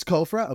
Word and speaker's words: COFRA: So COFRA is I think you said it right. It's COFRA. – Okay, good.